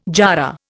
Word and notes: jarrah: Indian

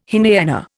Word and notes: Hinayana: Indian

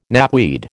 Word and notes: knapweed: American English

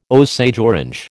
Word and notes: Osage orange: American English

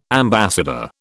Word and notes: ambassador: British English

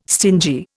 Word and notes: stingy: Indian

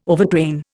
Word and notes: overtrain: Indian

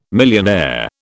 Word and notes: millionaire: British English

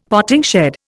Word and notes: potting shed: Indian

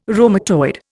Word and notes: rheumatoid: Indian

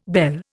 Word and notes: bel: Indian

